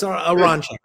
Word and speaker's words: Aronchick: Aronchick.